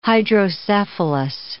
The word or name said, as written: hydrocephalus